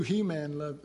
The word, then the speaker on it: Heman: A guy named Heman, or you could pronounce it He-Man.